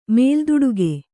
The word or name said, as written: ಮೇಲ್ದುಡುಗೆ